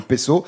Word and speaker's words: peso: je crois qu'on dit le peso, euh, qui est aussi d'ailleurs le, le, nom de l'unité monétaire d'Argentine, du Chili, de Colombie, du d'Uruguay, de Cuba, etc.